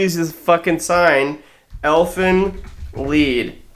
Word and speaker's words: Lied: And I recommend Elfin Lied, pronounced lead.